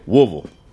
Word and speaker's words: Woodville: And that includes taking on new challenges, like trying his hand at high-speed game hunting in Woodville, Mississippi. – Or like the locals say, Woolville.